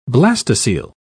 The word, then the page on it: blastocoele: British English